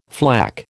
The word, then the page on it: flak: American English